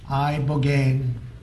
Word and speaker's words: ibogaine: Ibogaine. Remember the name. Is that pronounced relatively properly, would you say? – Yes.